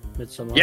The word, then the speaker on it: Midsommar: Midsommar.